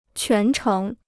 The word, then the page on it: 全程: quán chéng